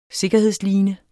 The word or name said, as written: sikkerhedsline